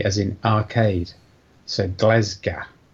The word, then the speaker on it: Glasgow: For the record, the pronunciation of the Scottish city is Glasgow. Right, the first vowel is an e, as in ten pence. So it's gle.